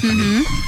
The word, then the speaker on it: Camus: Sí, se escribe Camus, pero se lee Camus.